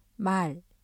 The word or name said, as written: 말